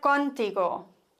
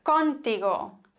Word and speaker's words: contigo: Dieser Satz könnte so falsch ausgesprochen werden, falsch betont werden. Me gustaría hablar contigo. Ungefähr. Contigo.